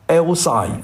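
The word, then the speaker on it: Eisai: 这个呢是在 6号 FDA 批准了，然后呢日本的研发单位叫做卫材，是一家日本的做药的公司，日文读音叫 Elsai 啊，叫药卫材公司，它的首席执行官叫做内藤敬夫，在东京的文京区的总部，在 7月7号开了这个记者招待会，就宣布这个药呢现在是可以通过使用了。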